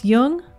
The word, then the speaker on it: Jung: Just be sure to pronounce Jung with a hard J. So with special thanks to Chiron Publications, this is Laura London, and you've been listening to Speaking of Jung.